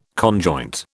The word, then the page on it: conjoint: British English